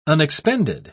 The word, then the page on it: unexpended: British English